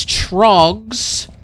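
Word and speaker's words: Tröegs: It is Trogs, which we've had Trogs on the show before. It is spelled T-R-O-E-G-S.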